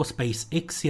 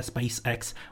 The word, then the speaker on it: SpaceX: Dosti mě rve uši výslovnost SpaceX jako SpaceX, je to tak půl na půl, šlo by to upravit na anglické SpaceX, u mě bohužel nikoliv.